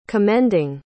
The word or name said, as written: commending